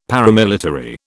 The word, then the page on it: paramilitary: British English